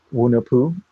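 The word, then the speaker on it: Hunahpu: The artwork itself is based off of or inspired by a Mesoamerican creation myth, which is there were hero twins, and I want to get the pronunciation right. It's Wunapu and Xibilanque, and they were twins.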